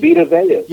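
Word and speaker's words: Vita Vea: And what, Vita Vey, is that how you pronounce that name? Vita Vey? – Vita Vey.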